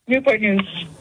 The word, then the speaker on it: Newport News: Or do they say Newport News? I've heard both ways. How do you say it? – Newport News.